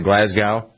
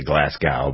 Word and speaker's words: Glasgow: That's kind of what some people are calling it in Glasgow. Is that how you pronounce it, Glasgow? Some people say Glasgow, but Glasgow.